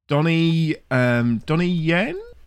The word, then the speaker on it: Donnie Yen: But this has got Donnie Yen, who is of Rogue One fame.